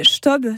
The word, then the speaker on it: Staub: 42 millions d'euros vont être investis dans l'usine CHTOB de Merville. – Oui, l'usine Staub, c'est comme ça que ça se prononce.